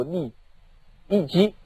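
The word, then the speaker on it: 郦食其: 这个义基两个字呢，它的文字呢是写成食齐，饮食的食，其他的齐，但是要念作郦义基。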